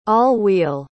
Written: all-wheel

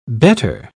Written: bettor

